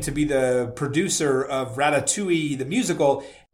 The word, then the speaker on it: ratatouille: I said Rat-tat-tui. And you should have, listen, the one thing you don't want to do on TikTok is outrage Gen Z, because they are brutal and they will come after you, right? So I had so many comments. You pronounced it wrong. How dare you? You pronounced it wrong. It's ratatouille. It's not ratatouille, you old man, like all that stuff, right?